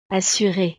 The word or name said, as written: assure